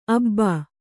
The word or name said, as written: ಅಬ್ಬಿ